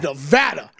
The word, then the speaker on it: Nevada: It was the first show I ever did here, I think, and it was with Matt, and I said Nevada during a break or something, and he was on me right away. It was, it's Nevada. I was like, what? And he's like, you're pronouncing it wrong.